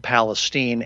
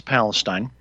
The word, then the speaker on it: Palestine: And it's only because I was trying to correct somebody who kept mispronouncing Palestine. They kept saying Palestine, and it's Palestine.